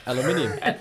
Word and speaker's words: aluminium: Really kind of like a aluminum. How do you say that? – Aluminium. – Like it's spelled. – Yeah, like it's spelled. – Yeah, the right way.